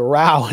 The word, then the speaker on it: row: Well, now we see with this kind of this rift, this is how do the British pronounce it? Row? It's a row inside the right.